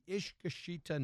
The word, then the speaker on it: Iskashitaa: So so today we've brought back the our lovely friends from the Iskashitaa Network.